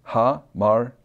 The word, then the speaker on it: hamartia: And then it tells us the phonetic pronunciation. So in other words, this is how you should pronounce it if you are an English speaker. And there it is, hamartia. That's how you pronounce it.